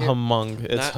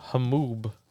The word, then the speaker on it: Hmong: Hamoob. Hamoob. Look, it's Hamoob. – Hmong. – It's not Hmong. I think so. – Yeah. – Mikey. Hmong. It's not Hmong. It's Hamoob.